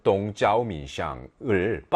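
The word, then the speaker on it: 동쩌오민샹을 (东交民巷): 예를 들면은 뭐 동교민항, 중국 발음으로 하면은 동쩌오민샹을 빤디루로 바꿨는데, 그러니까 원래는 이제 외국 대사관이 밀집한 그런 거리였나 봐.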